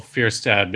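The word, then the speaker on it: Fjerstad: It's F J E R S T A D, and yeah, the J is basically just pronounced like a Y, like Bjork. So yeah, or like an umlaut almost, right? – Almost, sure, or a fjord.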